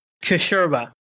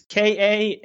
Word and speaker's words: Kashurba: Kashurba. – Yeah. I can't even repronounce that for you. So spell that for us. Everyone knows who you are. What's your last name spelled? – It's Kashurba.